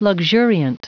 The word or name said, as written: luxuriant